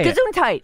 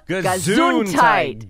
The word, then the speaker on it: Gesundheit: Gesundheit. – Gesundheit. – Put the emphasis on the zoom.